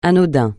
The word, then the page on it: anodin: francais